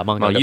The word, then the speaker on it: manga: It issued an advisory to artists and readers of graphic novels and manga. Is that how you pronounce it? – Manga. You did good.